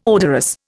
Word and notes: odorous: Indian

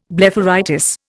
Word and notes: blepharitis: Indian

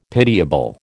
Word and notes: pitiable: American English

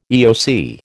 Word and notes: EOC: American English

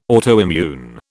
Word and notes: autoimmune: British English

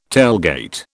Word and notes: tailgate: British English

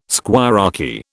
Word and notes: squirearchy: British English

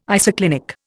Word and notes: isoclinic: Indian

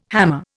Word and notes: hammer: Indian

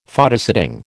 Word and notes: Photosetting: American English

